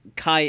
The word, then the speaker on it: Kaj: So even though it's spelled K-A-J, it's pronounce Kai Erik Erikson.